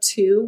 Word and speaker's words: two: I gave the example of two with the silent W, and it's in twin.